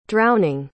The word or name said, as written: drowning